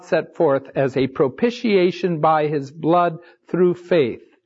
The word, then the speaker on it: propitiation: Propitiation.